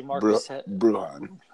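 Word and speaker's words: Bruján: Then Paul picks Brunjan, who obviously Brujan. – Okay, I'm terrible pronouncing names.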